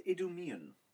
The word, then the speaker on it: Idumean: Herod, the son of Antipater, an Idumean? – Idumean. – He's an Idumean.